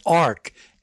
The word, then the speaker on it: ARCH: The library is called ARCH, A-R-C-H, but it's pronounced ARK, A-R-K.